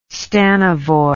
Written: stanovoi

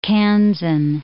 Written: Kansan

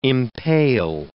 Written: impale

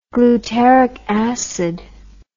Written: glutaric acid

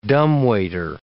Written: dumbwaiter

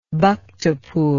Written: Bhaktapur

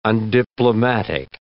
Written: undiplomatic

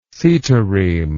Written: setireme

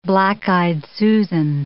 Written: black-eyed Susan

